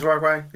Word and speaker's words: Markwayne: And listening to him talk, he sounds like the person who would kind of be named Mark Wayne, but it's one word. – Mark Wayne. Get in here, Mark Wayne. – Actually, no. Now that you say it like that, I can imagine it. Mark Wayne. – Yeah. Mark Wayne. – My name's Mark Wayne. He just says it, like, real fast. Like.